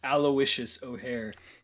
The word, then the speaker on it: Aloysius: So it's A-L-O-Y-S-I-U-S, Aloysius O'Hare.